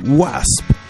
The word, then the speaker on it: W.A.S.P.: fundador de la banda de heavy metal Wasp. Se escribe W-A-S-P, Wasp.